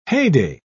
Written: heyday

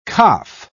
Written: kaph

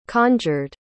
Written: conjured

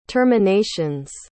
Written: terminations

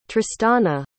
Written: tristana

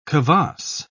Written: kvass

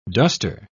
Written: duster

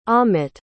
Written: amit

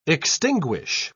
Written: extinguish

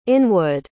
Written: inwood

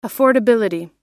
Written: affordability

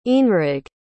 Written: eanraig

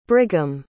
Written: Brigham

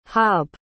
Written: haab

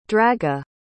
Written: draga

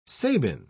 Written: sabin

